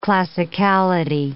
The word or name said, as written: classicality